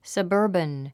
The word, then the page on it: suburban: səbə́ːrbən; サバーバン